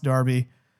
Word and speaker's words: Derby: Manchester Derby, spelled Derby, but pronounced Derby.